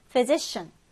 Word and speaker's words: physician: 이때 PH는 F 발음이 납니다. 그래서 physician, physician인데요.